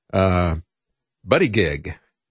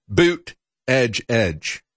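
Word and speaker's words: Buttigieg: It's spelled as if it was Buddy Gig, but and he is the first openly homosexual candidate for president. But it's actually pronounced, according to him, Boot Edge Edge.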